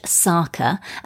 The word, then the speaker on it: Tsaka: The new range, Saka, and that's spelled T-S-A-K-A, so the T is silent, are based on ancient beauty recipes from Mozambique handed down by her grandmother.